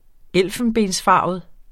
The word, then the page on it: elfenbensfarvet: -ˌfɑˀvəð; -ˌfɑːvəð